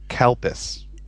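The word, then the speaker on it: Calpis: Recalled having a drink actually named Cool Piss was kind of cool. – Your pronunciation is slightly off. I believe you're talking about Kalpis.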